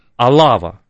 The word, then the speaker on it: Алава: И за это Наварра и Алава при франкизме, да, мы всегда говорим про то, что франкизм это такой унифицирующий. – Да-да-да. – Но Алава, прошу прощения, там ударение на первую букву,